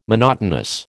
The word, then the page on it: monotonous: American English